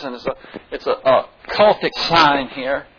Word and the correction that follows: Celtic: I'm sorry, I said it wrong. Celtic, not cultic. Celtic.